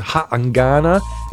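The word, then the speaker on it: Ha'angana: And we also sampled a brand new release from an artist known as Haangana, and that is spelled H-A-apostrophe-A-N-G-A-N-A.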